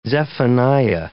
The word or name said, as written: Zephaniah